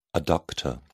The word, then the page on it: adductor: British English